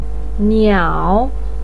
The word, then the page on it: niao: 3